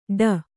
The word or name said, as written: ಠೂ